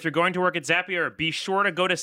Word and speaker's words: Zapier: Check out Zapier if you have not. It rhymes with happier because that's how it will make you feel.